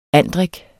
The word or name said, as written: andrik